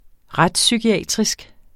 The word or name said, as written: retspsykiatrisk